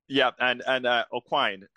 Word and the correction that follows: O'Quinn: did I say your name correctly, O'Quine? – It's O'Quinn. – Oh, Quinn, sorry about that.